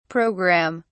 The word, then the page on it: program(me): British English